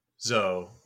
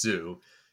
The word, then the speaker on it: Zou: So the inhabitants of Zo, which, as we were saying, the coincidence of it puzzle mistakenly being pronounced as zoo,